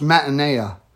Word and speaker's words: metanoia: That word repent there in the Greek is pronounced metanoia.